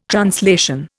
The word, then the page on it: translation: Indian